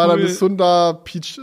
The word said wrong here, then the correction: Sundar Pichai: Äh, fuck, wie, wie, spricht man ihn aus? Sundar Pichai, oder?